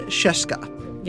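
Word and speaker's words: Scieszka: The author of The True Story of the Three Little Pigs is, I'm going to butcher this, John Sheska. – Yep. – Mm-hmm. You nailed it.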